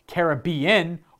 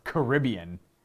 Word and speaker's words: Caribbean: Do you pronounce it Caribbean or Caribbean? I pronounce it both. I like Caribbean more. We are the Pirates of the Caribbean. I always loved that little tune. I don't know. I've always liked the Caribbean, but Caribbean, I think, is also accepted.